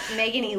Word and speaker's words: Meghan: So, my Instagram handle is at Meghan Elaine, and that's my name is spelled M-E-G-H-A-N.